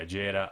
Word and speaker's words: Agera: Agera? Is that it? Agera? – Yeah, Agera RS.